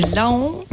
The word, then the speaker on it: Laon: Alors, ça s'écrit Laon, mais ça se prononce Laon.